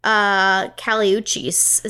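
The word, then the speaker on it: Kali Uchis: I really like Caliuchis. Is that how you say it? Yeah, you do.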